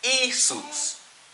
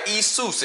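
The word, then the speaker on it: Isus (Ἰησοῦς): They would pronounce it Isus, right? Isus in Greek.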